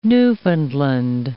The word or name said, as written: Newfoundland